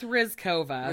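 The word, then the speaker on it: Ryzhkova: I think it's Rizkova, but her name is, I think. – What, Rizkova? – Oh, Rizkova, it's, yeah. Sorry. – It's R-Y-Z-H-K-O-V-A. Yeah.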